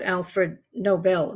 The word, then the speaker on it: Alfred Nobel: You know, I loved your anecdote about Alfred Nobel.